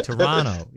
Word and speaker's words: Toronto: Toronto. You can't pronounce the second T. Toronto.